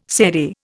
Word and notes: serai: Indian

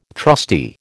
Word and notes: trusty: American English

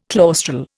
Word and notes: claustral: Indian

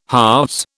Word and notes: halves: British English

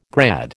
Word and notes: grad: American English

